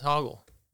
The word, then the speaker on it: Hoggle: Like, Hoggle? – Yeah, maybe. – Yeah, it's Hoggle. – Yeah, they kept getting his name wrong. Yeah. It was like Hogwarts.